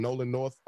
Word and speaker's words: Nolan North: Nolan North, I believe that's how you pronounce it. – Yes, gentleman's name.